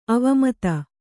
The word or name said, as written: ಅವಮತ